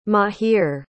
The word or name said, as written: mahir